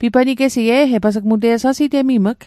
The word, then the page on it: pipanigesit: Mi'gmaq